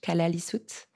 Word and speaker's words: Kalaallisut: You grew up in Greenland and you wrote your book in Greenland's official language, Kalaallisut.